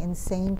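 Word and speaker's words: Insein: to a public prison called the Insane Prison. It's I-N-S-E-I-N, but you pronounce it insane, which all prisons have that aspect.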